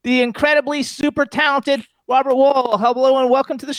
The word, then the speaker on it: Wuhl: Actually, it's pronounced... My family always pronounced it Wuhl, almost like the side of a building. – It is Wuhl, yeah. – Wuhl.